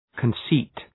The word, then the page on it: conceit: {kən’si:t}